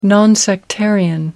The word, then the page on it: non sectarian: British English